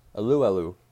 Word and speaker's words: Alualu: Our next one is Tyson Alualu. How to pronounce the name according to Wikipedia? Alualu. That's how you pronounce his name.